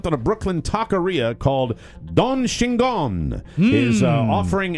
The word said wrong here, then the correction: Chingon: an incredible, did I pronounce that? C-H-I-N-G-O-N. – Chingon? – Don Chingon.